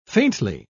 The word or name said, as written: faintly